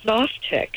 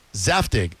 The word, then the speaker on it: Zaftig: It says adjective, Zaftig, Z-A-F, of a woman having a pleasingly plump figure, full-bodied, well-proportioned, Zaftig, or pronounced Zaftig.